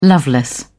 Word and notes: loveless: British English

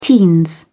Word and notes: teens: British English

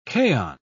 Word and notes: kaon: British English

